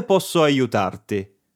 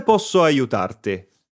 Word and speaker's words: posso aiutarti: Para ello podemos decir ¿cómo posso aiutarti?, que es muy similar a la estructura del español. ¿Cómo puedo ayudarte? A ver si pusiste atención a la pronunciación. Repite. ¿Cómo posso aiutarti?